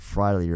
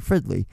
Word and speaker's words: Fridley: I pronounced it Friday earlier. Fridley.